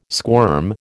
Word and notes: squirm: American English

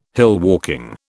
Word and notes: hillwalking: British English

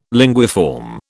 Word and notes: linguiform: British English

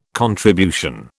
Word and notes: contribution: British English

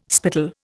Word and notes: spittle: Indian

